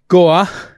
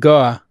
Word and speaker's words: Guo: And we've got a great guest, Greylock Partners, Sarah Gua, which is spelled G-U-O. But if you want to learn how to say it, if you say like Al Gore, if you were in Boston, you said Gua, that would be how you would say it.